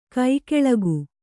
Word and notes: ಕಿಕ್ಕಿಲಿಕೆ: kannada

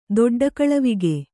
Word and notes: ದೊಡ್ಡಕಳವಿಗೆ: doḍḍa kaḷavige

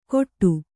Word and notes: ಗೊಟ್ಟು: goṭṭu

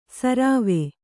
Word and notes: ಶರ್ವ: śarva

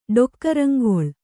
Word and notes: ಡೊಕ್ಕರಂಗೊಳ್: ḍokkaraŋgoḷ

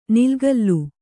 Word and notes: ನಿಲಗಾಲ: nilagāla

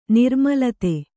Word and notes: ನಿರ್ಮೂಲಿತ: nirmūlita

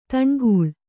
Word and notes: ದನುಕುಲ: kannada

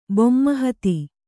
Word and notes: ಬೊಮ್ಮಹತಿ: bomma hati

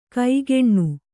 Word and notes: ಕೈಗೆಣ್ಣು: kaigeṇṇu

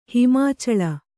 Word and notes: ಹಿಮಾಚಲ: kannada

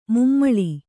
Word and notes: ಮಮ್ಮುಳಿ: Kannada

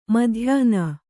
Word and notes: ಮಧ್ಯಾಹ್ನ: madhyāhna

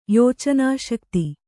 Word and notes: ಯೋಚನಾಶಕ್ತಿ: kannada

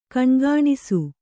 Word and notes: ಗುಣಗುಣಿಸು: guṇaguṇisu

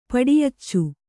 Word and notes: ಪಡಿಯಚ್ಚು: paḍiyaccu

